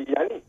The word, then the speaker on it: Yanni: In Greek, it'd be Yanni.